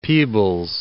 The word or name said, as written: Peebles